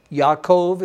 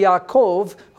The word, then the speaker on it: Yaakov: In this chapter, we'll see the elderly Jacob, Yaakov is how we say his name, Be'evrit, or in Hebrew. We'll see the elderly Yaakov, who God has renamed to Israel, is about to die.